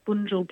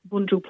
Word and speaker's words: Bunjil: At Bunjil Place. That's in Melbourne. So they're selling the tickets, but the show... Bunjil Place is a big theatre in suburban Melbourne, B-U-N-J-I-L it's spelt.